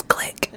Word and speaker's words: Gleick: I'm here for a talk by James Gleick called The... – Actually, it's pronounced James Glick.